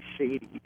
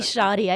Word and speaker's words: Shadi: I mean, you can tell by the way they treated how do you pronounce her first name? I always say Shady in my mind. – I say Shoddy. I said Shoddy, but S-H-A-D-I, Shoddy Pitowski?